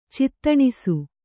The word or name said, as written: ಚೇತನಿಸು